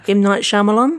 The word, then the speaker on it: Shyamalan: So M. Night Shyamalan.